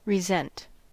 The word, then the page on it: resent: American English